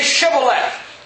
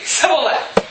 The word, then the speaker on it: Shibboleth: And they would say, "Okay, well, say Shibboleth." And they would say, "Sibboleth." For he could not frame to pronounce it right.